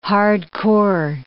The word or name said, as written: hard-core